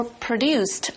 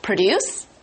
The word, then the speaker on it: produce: In our article,Produce is a noun, and it refers to the food or other things that have been grown or produced on a farm to be sold. So, produce is the verb, and produce is the noun. – Produce, P-R-O-D-U-C-E, 这个字呢，重音在第一节的时候呢，念作 produce, 记得哦，是农产品的意思。那这个字呢，当动词用的时候，拼法一样，但是重音放在第二节，我们念作 produce, 是生产、制造的意思。